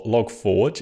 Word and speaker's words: Log4j: So Log4j is this, or Logforge, as I hear it's actually pronounced by the Java developers, but I'm going to call it Log4j because I think that's its real name.